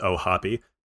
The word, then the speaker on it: O'Hoppe: So the easy answer here, post 250 for catchers, I think is Logan O'Hoppe, And uh, I actually just learned today that it is pronounced O'Hoppe because of Eric Cross's panel on prospects today.